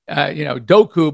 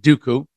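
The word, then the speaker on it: Dokoo: It's D-O-K-O-O. To me, it would be, Doku, but they pronounce it Dooku.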